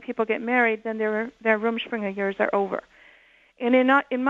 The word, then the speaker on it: Rumspringa: Rumspringa.